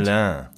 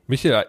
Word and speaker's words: Michelin: Michelin. – Oh, Entschuldigung. Michelin.